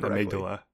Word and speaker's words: amygdala: The part of our brain most often associated with fear and that fight or flight response is the amygdala. I really hope I'm pronouncing that right. – Amygdala. – I'm going to say it a million times. – Amygdala. – It's amygdala.